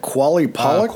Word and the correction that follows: Qalupalik: Uh, Qalupalik, yeah.